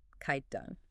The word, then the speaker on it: Khyda: Khyda. – It is spelled Khyda. The crucial detail to memorize is that it begins with a somewhat silent, aspirated KH.